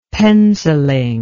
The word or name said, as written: penciling